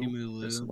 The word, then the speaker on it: Simu Liu: but Simu Liu. – Simu Liu.